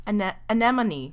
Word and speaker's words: anemone: And the nectar mixed with the blood and actually created the first, I'm not going to say this right, red blood anemone flower? I've never heard of this flower before. – Anemone.